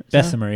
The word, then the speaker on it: Bessemer: New Orleans, how do you pronounce these? You're much better. The last name, it's like Louis Bessemer? – Bessemer, yeah.